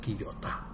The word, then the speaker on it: Kiyota: ¿Has visto la limpiadoría Kyoto? O Kiyota es como se pronuncia.